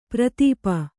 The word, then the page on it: ಪರಿತಾಪ: pari tāpa